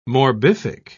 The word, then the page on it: morbific: British English